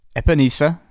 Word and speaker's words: Epenesa: That's AJ Epenesa.